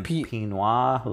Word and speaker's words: pinot: And then pinot. – Like, pinot? P-I-N-O-T. – Yeah, but it's pronounced... Pinot. Like, it has to do with wines.